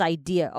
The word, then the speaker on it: idea: 有时候呢，就是要轻松一下，休息一下。那我们来看一下我们今天的 sentence breakdown, aka tips, 欢乐时光。首先第一个看到的是 this idea. Okay, 这两个字麻烦要念在一起连音哦。 This idea, this idea. 后面那个 ea 是 ear, ear。 Idea, 好，不用再拉长了。